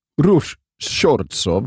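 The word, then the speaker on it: Ruch Chorzów: Får jag höra dig säga Rush Shortsov? – Rush Shortsov? – Du gör det fan bra.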